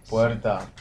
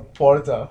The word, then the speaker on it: puerta: Puerta. Is it puerta or porta? – Puerta.